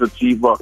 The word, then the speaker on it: Atiba: Yo, my name is Atiba. Rhymes with Ariba.